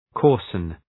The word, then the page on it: coarsen: 'kɔ:rsən